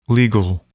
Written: legal

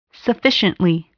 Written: sufficiently

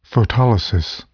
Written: photolysis

